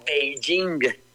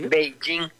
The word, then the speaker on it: Beijing: P-E-K-I-N. Cuando la capital de China se pronuncia Beijing. – Sí. – Beijing.